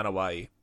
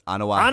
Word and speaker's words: Anoa'i: Back is that how you pronounce it? – Yeah, Anawahi.